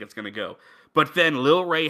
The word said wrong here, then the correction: Lil Rel Howery: how do you say his name? – Lil Rel Howery.